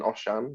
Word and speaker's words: Osian: My name is Oshan Lewis. Call me Osh, like everybody else is. It's a bit easier to pronounce than Oshan, because it's, you know, not a very English and Britain-friendly name.